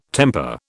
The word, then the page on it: temper: British English